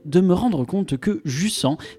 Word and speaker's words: Jusant: Ce jeu, c'est Jussan, et oui, Jussan et pas jussent comme je l'avais prononcé dans ma chronique abordant les démos.